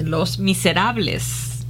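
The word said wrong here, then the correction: Les Misérables: Les Miserables.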